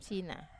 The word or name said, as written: sina